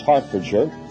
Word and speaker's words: Hertfordshire: That's pronounced Hertfordshire.